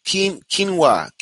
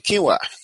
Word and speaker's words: quinoa: Pronunciation. Quinoa. Okay. Quinoa. Got it.